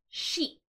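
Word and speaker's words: she (使): It means use, and it's pronounced she.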